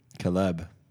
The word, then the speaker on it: keleb: K-E-L-E-B. – Caleb. – So... I'm trying to look at the pronunciation. Yeah, it'd be like Caleb.